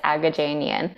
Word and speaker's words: Agajanian: Well, actually, you did, for your credit, you pronounced it the Armenian way, which is technically correct. And my family would say, oh my gosh, that's even better. But my English pronunciation that I've adapted thinking it's easier is Agajanian.